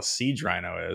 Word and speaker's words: Siege Rhino: I know a card called Sage Rhino. I don't know what the hell a Siege Rhino is. – No, I said it right. – You said it the right way.